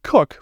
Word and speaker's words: Koch: It's only four letters long, but K-O-C-H doesn't immediately read as Cook for most people, and I've seen so many variations of how to spell my first name as well.